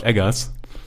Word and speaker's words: Eggers: Robert Eggers oder Edgers, ausgesprochen mit Doppel G.